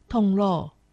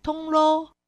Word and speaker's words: 铜锣: 在今天节目中介绍客家文化重点发展区苗栗县铜锣乡，客家话四线腔发音铜锣，海陆腔发音铜锣，欢迎听众朋友们一同收听。铜锣车站是三线铁路上的一站，铁道带来的改变，货物、人口、文化交流，也让这个小镇与外界连结。但有趣的是，铜锣仍然保持慢，不像城市急促，更像呼吸。